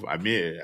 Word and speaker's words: Amir: Today with me, I have Amir.